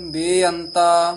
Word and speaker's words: बेअंता: ध्यान नाल उच्चारण करना है, पहला बेअंत जेड़ा है सियारी दे नाल है, इनू छेती बोल देना है, परे बेअंत। दूसरा बेअंता लां दे नाल है, इनू दीर्घ करके बोलना है, शुद्ध बोलना है, ये नहीं भी दोवे बेअंता बेअंता कह जाना है।